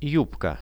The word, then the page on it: jubka: Russian